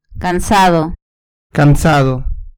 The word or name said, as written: cansado